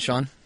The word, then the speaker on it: Sean: But what do you got, Sean? – You can find me on Twitter at Sean Faust, S-E-A-N, for those of you who don't, spell it the wrong way.